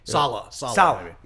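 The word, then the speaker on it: Saleh: I think it's Seha. I can't really pronounce his name. That's why I was like. – Sala. – Yeah, there you go.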